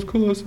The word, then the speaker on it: Kalesa: I say Kalesa or Kalesa, I think was, I first called him. He's like, no, it's Kalesa.